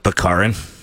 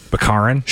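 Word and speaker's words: Baccarin: Bakarin. – Bakarin.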